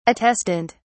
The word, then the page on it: attestant: British English